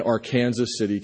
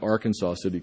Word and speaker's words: Arkansas City: It left from the Fred Harvey Hotel in Arkansas City, Kansas, which is pronounced that way and not Arkansas City, Kansas, as I learned when I said it wrong on public radio in Kansas City last week.